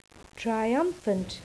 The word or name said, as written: triumphant